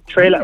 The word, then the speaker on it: Taelor: Taelor. Mm-hmm. It's basically like Taylor spelled with an R, basically.